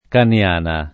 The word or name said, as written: Caniana